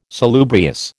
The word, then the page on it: salubrious: American English